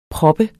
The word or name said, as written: PROPPENDE